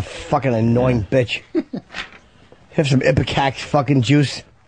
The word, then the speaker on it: ipecac: How do you say that word? I-P-E-C-A-C? I can never say that word. – Ipecac. – All right, Ipecac.